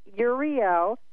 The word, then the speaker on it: Uriel: Your archangel is Uriel. U-R-I-E. – How do you spell it? – Okay, U-R-I-E-L, Uriel.